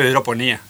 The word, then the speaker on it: hidroponía: Así es, le dice hidroponía, es con el acento en la I.